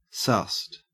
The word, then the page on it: sussed: Australian English